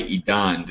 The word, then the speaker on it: Edan: So today we will be discussing Beauty and the Beat by Edan. Did I say that right? – Yeah, it's Edan.